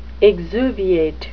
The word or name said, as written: exuviate